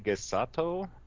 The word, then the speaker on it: Shigesato: So what that means is basically we had Shigatsu Itoi. I believe that's how you pronounce his name. I always screw up the pronunciation. – Shigesato? – Basically, you have this famous writer.